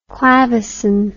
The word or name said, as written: clavecin